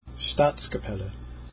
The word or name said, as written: staatskapelle